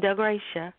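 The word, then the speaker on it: Del Gratia: D-E-L. Mm-hmm. – That is my whole name, Del Gracia.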